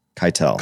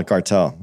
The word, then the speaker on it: Keitel: Harvey Keitel. – Keitel. You about to fuck me up. You said Harvey Keitel? – Keitel, yeah.